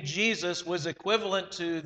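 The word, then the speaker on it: Jesús: Now, I do know that in some cultures, Jesus, or as it's pronounced, Jesus, is a common name, but not in America.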